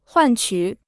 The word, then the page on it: 换取: huàn qǔ